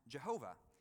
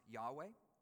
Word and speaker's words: Yahweh: It's actually just four letters in the Hebrew, Yod, He, Vav, He, sometimes called the Tetragrammaton, which is sometimes pronounced Jehovah. But we know now that it's actually more likely to have been pronounced Yahweh.